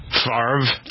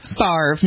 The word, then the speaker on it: Favre: Favre? – Favre, not Favre.